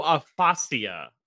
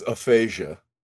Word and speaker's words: aphasia: No, aphasia, I believe is the way it's pronounced. – It's not pronounced that. It's aphasia.